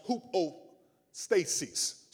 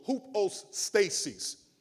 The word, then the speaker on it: hupostasis: Well, the word substance in Hebrew is pronounced hupostasis. So hupostasis.